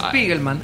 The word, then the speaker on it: Spiegelman: como la novela gráfica Maus de Spielman. – Spiegelman, sí, Art Spiegelman.